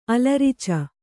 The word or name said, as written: ಅಲರಿಚು